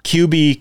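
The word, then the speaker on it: Quebee: It's Q U E B E E. So Q, I think that's how you pronounce it. QB Quick. – No way. But the last name is Q U I C H E. So maybe I'm pronouncing that wrong, wrong too.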